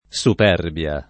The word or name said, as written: superbia